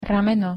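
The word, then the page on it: rameno: Slovak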